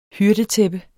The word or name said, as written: hyrdetæppe